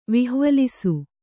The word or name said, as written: ವಿಹ್ವಲಿಸು